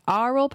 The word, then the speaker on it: aural: aural person I know. Audio, A-U-R-A-L, not O-R-A-L. – You pronounced it correctly.